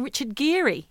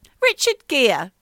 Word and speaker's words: Richard Gere: Did you hear of that one starring Richard Geary? – No, who's Richard Geary? – Well, we'll go back to that. Richard Gere. Yeah. It's how my mother pronounces his name.